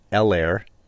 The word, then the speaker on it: Helaire: All right, so Clyde Edwards, as we all learned last night, Helaire, the H is silent.